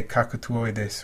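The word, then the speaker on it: cacatuoides: I say cacatoides, which is how an Italian would probably say it. That's why, you know, I'm using the Italian pronunciation.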